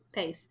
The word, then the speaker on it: Pais: Raphael was Josh Pais. How would you pronounce that? – Pace.